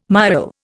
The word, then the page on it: miaul: Indian